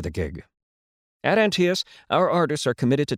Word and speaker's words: Antaeus: Rhymes with Antias. That is literally how we tell people to pronounce our name. It sticks. Antias, come see us.